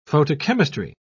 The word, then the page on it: photochemistry: British English